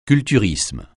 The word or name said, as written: culturisme